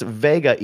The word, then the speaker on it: Wega: It's pronounced Vega, even though it's spelled Wega. So, yeah.